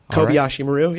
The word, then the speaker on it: Kobayashi Maru: The new album by Four Lights is called Kobayashi Maru. Am I pronouncing that correct? – Yeah, that's good. It's Kobayashi Maru, yeah.